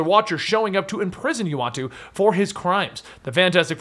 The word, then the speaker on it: Uatu: It's Utah, right? – Well, it wasn't originally. Well, pronunciation. – Yeah, it's Uatu. – Uatu, that's it.